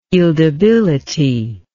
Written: yieldability

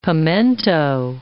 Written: pimiento

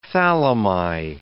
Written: thalami

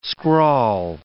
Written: scrawl

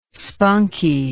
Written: spunky

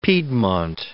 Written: piedmont

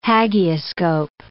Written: hagioscope

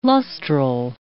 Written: lustral

